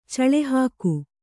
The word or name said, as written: ಚಳೆಹಾಕು